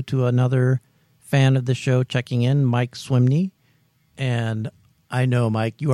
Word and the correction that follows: Swinney: Or, oh my God, now I'm double wrong. Mike Swinney. I can't get his name right for the life of me. So he's gone from Mike Swimney to Mike Swimmy to Mike Swinney. – It's because you want to put swim in there.